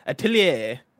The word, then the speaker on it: atelier: Atelier. Atelier. Is it French? – Yeah, you gotta like put the if it's French, then I know the pronunciation, because it's atelier, you know, in that case.